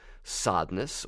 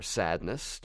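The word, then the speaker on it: Sadeness: And on Soundcheck today, we're finally talking about Sodness, or Sadness, depending on how you want to pronounce it, the 1990 song by Enigma that is part of our regular opening montage when we do That Was a Hit.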